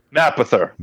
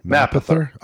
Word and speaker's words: Mapother: Yeah, it's a great, and our man William Mopather is really good in it. He's really good too. – Mopather. – Isn't that how you pronounce it? – Mapother. – Mapother.